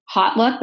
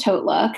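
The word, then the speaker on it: HauteLook: And when I quit Deloitte, it ended up working out where I got a job at Hotlook, which is, some people think know of it as Hotlook, but it's pronounced Hotlook.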